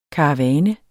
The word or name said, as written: KARAVANERNES